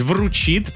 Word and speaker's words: вручит: Все-таки правильно говорить «вручит», поэтому здесь 1000 не зарабатываете.